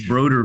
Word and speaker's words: Brodeur: I, I like to tell people the easiest way to think about it is that it rhymes with voter and so you can be a Broder voter.